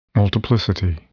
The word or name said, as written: multiplicity